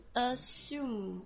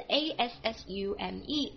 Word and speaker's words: assume: 好了，第八个 assume，看一下发音分开来 assume，动词假设、假定、认为的意思，a s s u m e， assume，要闭嘴巴的 assume。